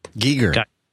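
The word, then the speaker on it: Giger: But in the middle, the main art part of the poster proper is a piece by Geiger. I think I'm pronouncing that correctly. I'm not sure. – Geiger.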